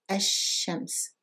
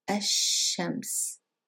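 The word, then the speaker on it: الشمس: So we said that because sheen is a sun letter, we don't pronounce the lam and we double the sheen. So Ashams, Very good.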